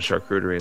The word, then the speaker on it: charcuterie: You know, I make a lot of my own charcuterie and stuff like that. So I have my charcuterie. How do you say that? – You got it. Charcuterie. You got it right.